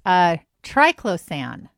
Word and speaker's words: Triclosan: All right, let's see if I can pronounce this. Triclosan. That seems like I said it correctly. – Actually, no, I want to applaud.